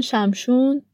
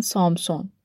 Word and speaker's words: شمشون: بعد از مدتی اون زن پسری به دنیا میاره که اسمشو میذارن شمشون. بعضیا میگن شمشون، بعضیا میگن سامسون. ولی چون قرار شد ما بر مبنای ترجمه آقای سیار بریم، من شمشون میخونم برای شما. ولی اگه جای دیدین سامسون، همین شمشونه.